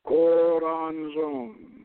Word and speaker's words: Choronzon: The name is pronounced Keo, Keo, Ron, and Zodan in seven syllables, and it means he who is the basis of form. Choronzon.